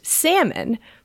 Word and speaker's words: Salmon: A listener reached out to us, and you know how in the Salmonella episode, we were like, oh, is it pronounced Daniel Salmon or Daniel Salmon? – Yeah. – The person who Salmonella is named after. – Uh-huh. – So someone tweeted at us and said it's pronounced Salmon because he is one of her ancestors.